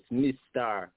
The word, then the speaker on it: MyStar: Don't get it confused. It say MyStar. MyStar Progress, but you say it like the Jamaican them, you know? You're my star, yes, MyStar. MyStar Progress. Mr. Progress. That's the name, baby. Let's get it right, get it tight.